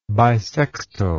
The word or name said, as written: bissextile